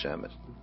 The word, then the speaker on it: Shamil: And his name is actually Shamwil, but it just became pronounced Shamil.